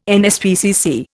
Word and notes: NSPCC: Indian

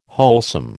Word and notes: wholesome: American English

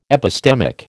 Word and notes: epistemic: American English

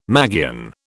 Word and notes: magian: British English

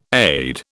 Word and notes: AID: British English

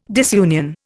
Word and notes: disunion: Indian

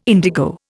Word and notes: indigo: Indian

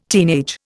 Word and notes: teenage: Indian

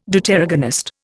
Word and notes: Deuteragonist: Indian

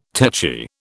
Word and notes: tetchy: British English

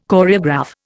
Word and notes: Choreograph: Indian

